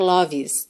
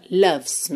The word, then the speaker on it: loves: Loves, atenção, para você não pronunciar a letra E, não é loves, tá? E não é lo também, é l, l, loves me. Loves, do V você vai pro S, tá?